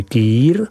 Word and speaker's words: Kir: Víno Aligoté je nenahraditelné i v proslaveném nápoji Kir, vyslovuj Kýr, který své jméno získal po starostovi města Dijonu Felixi Kirovi.